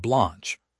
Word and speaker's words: Blanche: Todd Blanche, pronounced B-L-A-H-N-C-H, has been confirmed as Deputy Attorney General.